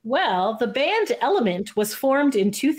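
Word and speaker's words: LMNT: Which Glee star was once a member of the early 2000s boy band Element, which is written capital L, capital M, capital N, capital T, but pronounced Element?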